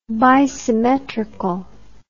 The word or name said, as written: bisymmetry